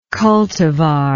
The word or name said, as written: cultivar